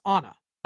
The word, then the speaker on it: Anna: And today I caught that it's Anna, not Anna. – Why would it be Anna versus Anna? – Yeah. – Why? – He says she pronounced it. She pronounced her name with the old world emphasis. Anna. – Oh, with the old world emphasis. Okay. I didn't understand what that was. – A-H hyphen N-A-H.